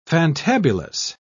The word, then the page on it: fantabulous: British English